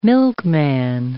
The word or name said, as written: milkman